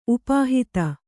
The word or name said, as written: ಉಪಹತಿ